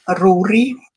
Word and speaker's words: Rúrí: Rúrí.